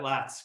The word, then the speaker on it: Latz: Last name's pronounced Latz. Is that right? – Got it. Latz. Correct.